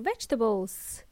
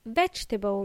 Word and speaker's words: vegetable: It's a vegetable or vegetables. In Spanish, this is vegetales o verduras. It is pronounced vegetable, not vegetable. It's not vegetables. It's vegetables.